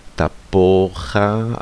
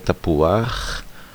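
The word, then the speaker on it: tapuach (תפוח): Ke stejnému vplížení, jako je například jadua místo jada, čili vplížené patach, patach furtivum, a ze stejného důvodu, jako se neříká tapocha, ale tapuach, a lucha, ale luach.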